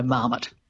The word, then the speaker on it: marmot: But I think it's also been in the languages, I don't know, I think it was at 1600s, I think. So it's had a lot of time, you know, to sort of be squished into an English model of stress, which would be the stress on the first syllable, so marmot.